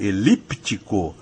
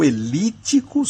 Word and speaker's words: elíptico: Então é isso, querida ouvinte, ou pode ser elíptico com o P que não é mudo porque é pronunciado, ou elítico sem o P e, portanto, não existe a letra P nessa outra opção.